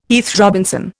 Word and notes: Heath Robinson: Indian

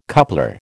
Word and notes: coupler: American English

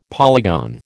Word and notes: polygon: American English

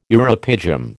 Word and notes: uropygium: American English

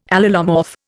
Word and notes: allelomorph: Indian

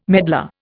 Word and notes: medlar: Indian

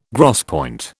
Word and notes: gros point: British English

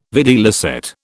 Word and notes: videlicet: British English

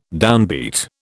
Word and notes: downbeat: British English

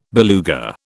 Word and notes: beluga: British English

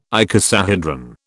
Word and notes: icosahedron: British English

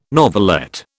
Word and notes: novelette: British English

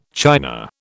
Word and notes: china: British English

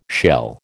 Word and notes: shell: American English

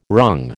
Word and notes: wrung: American English

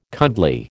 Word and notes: cuddly: American English

